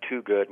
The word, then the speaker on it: Twogood: My name is Dr. Dan Twogood. My last name is spelled like the number two and good. So that's T W O G O O D.